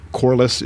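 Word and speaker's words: Corless: My name is Kirk Corliss. It's spelled K-I-R-K. Last name is spelled C as in cat, O-R-L-E-S-S.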